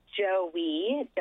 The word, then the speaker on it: Joey: It's Joey, like the baby kangaroo.